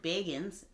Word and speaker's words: Bagans: This guy, Zach Bagans, or I was pronouncing it Bagans, but he pronounces it Bagans.